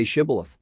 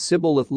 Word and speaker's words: Shibboleth: They all worship at Zor, and they sing out of such and such a selection, and as for those who cannot say Shibboleth, and lay a pretty good stress on the H, but who pronounce it Sibboleth, let the fords of the Jordan be taken, and let them be put to death.